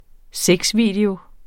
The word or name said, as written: sexvideo